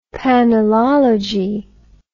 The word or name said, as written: panelology